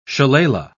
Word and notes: shillelagh: British English

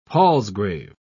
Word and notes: palsgrave: British English

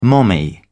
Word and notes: mommy: British English

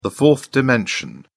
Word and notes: fourth dimension: British English

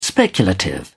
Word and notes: speculative: British English

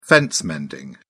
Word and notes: fence mending: British English

